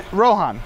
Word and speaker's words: Rohan: How do you say it? – Rohan. He's saying it right.